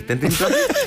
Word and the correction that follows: Trending Topic: Trending Topic.